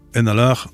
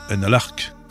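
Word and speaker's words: An Alarc'h: Nous allons rester dans la même mouvance que cet album, Groingaise Lille aux Femmes, avec le trio Père Vary Kervarek, avec Enalar, qui veut dire le cygne en breton, et on ne prononce pas Enalark.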